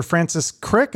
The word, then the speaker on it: Francis Crick: So then you meet this neuroscientist, Dr. Francis Crick.